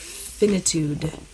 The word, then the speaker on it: finitude: finitude. Is that how you pronounce that? – Yeah.